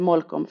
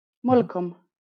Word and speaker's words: Molkom: Molkom. Jag tror man uttalar det Molkom, men nu är jag ingen värmlänning.